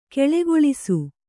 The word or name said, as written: ಘುಳುಘುಳಿಸು